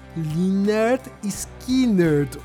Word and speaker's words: Lynyrd Skynyrd: Eles lançaram o primeiro álbum deles, chamado Pronounced Leonard Skinner. Ou seja, eles colocaram no nome do primeiro álbum deles, escrito assim, é, é, é traduzindo para português, seria pronunciado Leonard Skinner. Ou seja, ensinando o público como falar o próprio nome da banda.